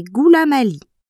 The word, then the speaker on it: Goulamaly: Bonjour, je m'appelle Anaïs et mon nom de famille est Goulamali. Ça s'écrit G-O-U-L-A-M-A-L-Y.